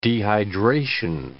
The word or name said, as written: dehydration